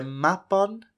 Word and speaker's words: Mabon: The salmon, an ancient creature, takes them down the Severn River to Caer Loyw, modern-day Gloucester, where Mabon has been kept prisoner since he was but a baby. Mabon, or perhaps you may have heard it mistakenly pronounced as Mabon or Mabon.